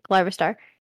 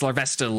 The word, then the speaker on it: Larvesta: It's Larvesta, like a... – Larvesta. – Like okay. Larva and then Esta.